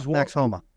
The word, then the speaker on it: Homa: What happened in one of the golf tournaments, and I'll tell you, in Homa, I think that's how you pronounce it, H-O-M-A. – Max Homa.